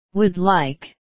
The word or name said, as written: would like